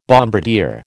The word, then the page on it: bombardier: American English